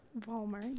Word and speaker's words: Vollmer: Vollmer, yes.